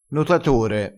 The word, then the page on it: notatore: dialettale